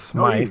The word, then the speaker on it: Smythe: No E, just Smythe. No E.